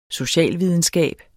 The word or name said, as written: socialvidenskab